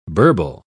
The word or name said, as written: burble